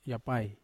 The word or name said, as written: apai run